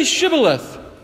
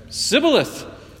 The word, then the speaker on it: Shibboleth: When he said, "No," they said to him, "Then say Shibboleth." And he said, "Sibboleth," for he could not pronounce it right.